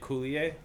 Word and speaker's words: Coulier: She was John Stamos's Dave Coulier, is that how you say it? – Wife? Yeah. – Dave Coulier? – Yeah, there you go.